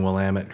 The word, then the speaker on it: Willamette: And I applaud you for saying Willamette correctly. – Oh, that's how you say it. If you say it another way, you will get punched in the face.